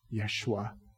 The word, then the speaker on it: Yeshua: Yeshua.